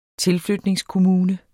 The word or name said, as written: tilflytningskommune